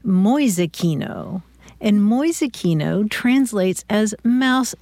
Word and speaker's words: Mäusekino: M-A umlaut U-S-E-K-I-N-O, Mäusekino.